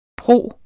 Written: pro

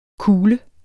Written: kule

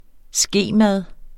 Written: skemad

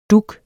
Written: dug

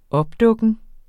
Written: opdukken

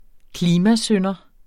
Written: klimasynder

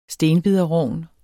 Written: stenbiderrogn

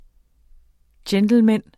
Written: gentleman

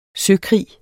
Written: søkrig

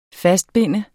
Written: FASTBUNDET

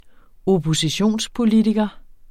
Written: oppositionspolitiker

